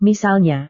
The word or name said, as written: misalnya